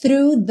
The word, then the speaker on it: through: Crash through the surface. Crash through. Through. Fa-fa-fa, linguinha lá no dente da frente. E já puxa no R. Through. Through. O GH não é pronunciado.